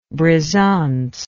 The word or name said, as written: brisant